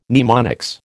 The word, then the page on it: mnemonics: American English